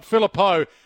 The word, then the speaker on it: Philippou: There's another one here that's come through from a bloke by the name of Steve who said that you've got the pronunciation of your name wrong. It's Philippou, not Philippo. Like it's Postecoglou, like it's Christou. So you, apparently you don't even know how to pronounce your own name. – It's Macedonian, so the pronunciation's different from that side of it. But no, I'm not. It's definitely Philippo.